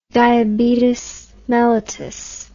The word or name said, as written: diabetes mellitus